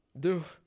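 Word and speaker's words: Dubh: It's Celtic god Cromdub, or Dub. It's D-U-B-H. – Do.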